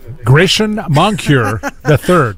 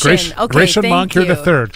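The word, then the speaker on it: Grachan Moncur III: You're going to have to help me pronounce this trombonist, Gracken Moncur III? – Gracien Moncur III. Yes. – Gracien Moncur III.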